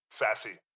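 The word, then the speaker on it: Sasse: Is that how you pronounce his name? Sassy. Apologies. – Yeah. Sassy. Ben Sassy.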